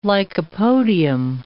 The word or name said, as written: lycopodium